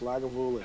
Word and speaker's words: Lagavulin: Yes, Lagavulin.